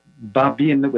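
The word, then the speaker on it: Babyn: So Baba Yar is the Russian name, and Babin with an N is the Ukrainian pronunciation.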